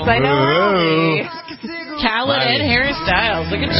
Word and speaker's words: Khaled: Khaled.